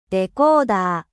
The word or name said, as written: decodeur